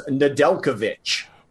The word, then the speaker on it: Nedeljkovic: Yeah, that was one that I had to hear it a couple times because it is not pronounced the way it looks. But it is Nedeljkovic. – Well, there's an H on the end of the sound, but not on the end of the word.